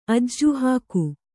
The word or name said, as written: ಅಜ್ಜುಹಾಕು